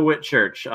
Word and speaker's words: Whitchurch: CO Whitchurch. It's the simple way to spell it, I tell people. It's a combination. My first and last name is just Cow Hit Church, at Cow Hit Church on Twitter and on the Action Network app.